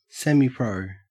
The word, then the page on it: semipro: Australian English